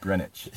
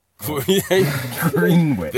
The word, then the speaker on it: Greenwich: Cool, it's pronounced Greenwich. – Oh, whatever. – Greenwich. – The Greenwich Meridian.